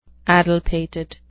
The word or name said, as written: addlepated